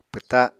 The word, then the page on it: pwta7: Apatani